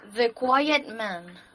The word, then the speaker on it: The Quiet Man: El eh, vídeo este de, de, ¿Esto cómo se pronuncia? ¿De Quiet Man? ¿De Quiet Man? – The Quiet Man.